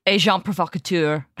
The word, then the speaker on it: Agent Provocateur: Agent Provocateur, which is, do you say Agent? How do you pronounce that? – Agent Provocateur. – Right, great.